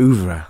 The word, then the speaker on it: oeuvre: But it's also one of those words you have to pronounce it in a French accent. You have to say oeuvre.